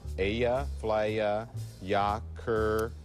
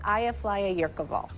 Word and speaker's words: Eyjafjallajökull: Eyjafjallajökull. – In Iceland. – Ball. – With an unpronounceable name. – Eyjafjallajökull. – Eyjafjallajökull. Am I pronouncing that correctly? – Nearly.